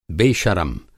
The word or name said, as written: बेशरम